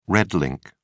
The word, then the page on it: red ink: British English